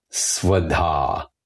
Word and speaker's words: Svadha: But just at the very end, say the word Svadha, S-V-A-D-H-A, long A at the end, Svadha.